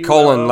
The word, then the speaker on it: Cullen: That's how you say it? – Mm-hmm. Cullen, like the people, like Edward Cullen.